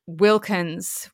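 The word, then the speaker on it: Wilkens: Um, and my surname is Wilkins, which is most commonly spelt W I L K I N S, but I am E N S.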